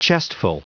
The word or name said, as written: chestful